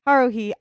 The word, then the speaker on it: Haruhi: It revolves around Haruhi. Is that how you pronounce it? – Haruhi.